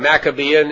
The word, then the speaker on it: Maccabean: So the Maccabean period, and Maccabean is M-A-C-C-A, Macca, and then Bean, B-E-A-N, pronounced Maccabean, from 165 to about 163 B.C., or rather, 165 to 63 B.C.